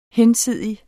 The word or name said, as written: hinsidig